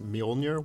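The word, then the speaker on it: Mjölnir: Thor and his hammer, which is apparently pronounced Mjolnir, which I just can't believe. But anyway, when I was a kid, I pronounced it differently.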